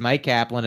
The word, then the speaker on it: Myq Kaplan: My name is Mike Kaplan. It's spelled weird. MYQKAPLAN.